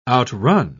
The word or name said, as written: outrun